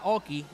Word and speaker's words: AUKEY: Es una marca Oki, la marca se escribe uh-huh. AUKEY.